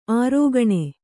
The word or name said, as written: ಅರಗಿಣಿ